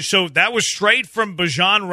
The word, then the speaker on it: Bijan: lot of people say Bijan.